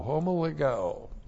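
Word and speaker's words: homologeo (ὁμολογέω): Looks like that in the Greek, H-O-M-O-L-O-G-E, long O, homologeo.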